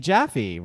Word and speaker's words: Jaffe: They are from Betsy Jaffe, rhymes with Taffy.